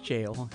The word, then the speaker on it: gaol: The word jail, when spelled G-A-O-L, pronounced jail, it's a British variant, G-A-O-L.